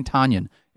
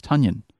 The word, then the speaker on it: Tonyan: Everyone's saying it wrong. Everyone's saying Tonyan. It's Tonyan. Get it? – Tonyan.